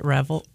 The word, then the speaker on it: Reveille: Reveille? – Yeah. I don't know why. – Anybody that's anywhere close to any association with the military knows what Reveille is.